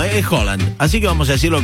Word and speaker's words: Haaland: Bocinia y Haaland son... Perdón, Haaland no, hay que empezar a decirle Haaland. Porque en noruego la doble A se pronuncia como una O.